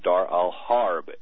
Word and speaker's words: Dar al-Harb: In Arabic, it's pronounced Dar al-Islam and Dar al-Harb, H-A-R-B.